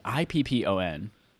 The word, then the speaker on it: Ippon: I wonder if we're even pronouncing that correctly. Ippon. – Yeah, you got it right.